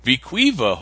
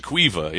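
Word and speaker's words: We-Ko-Pa: And I made sure that's how you say it. I actually watched a video about the property the other day. So it's good. Viquiva, yeah.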